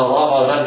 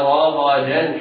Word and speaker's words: رغدا: إذن انتبه جيدا، لا تنطق وكلا منها رغدا حيث شئتما وتخلل الدال ضاد، لا، انطق هكذا: وكلا منها رغدا حيث، رغدا حيث تنوين بعده حاء فحكمه الإظهار الحلقي.